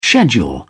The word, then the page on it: schedule: British English